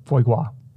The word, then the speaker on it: foie gras: I'm probably mispronouncing that. – It sounds right. – I'm looking at the pronunciation. Is it G R A S? – Mm-hmm. Yeah, I That's think gras. you got it.